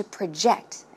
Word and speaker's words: project: In this case, the stress goes in the second syllable. As you can see, it's not project. When it's a verb, it should be project. Project. One more time.